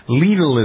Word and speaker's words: Lidl: Lidl is how they're pronounced. It rhymes with beetle.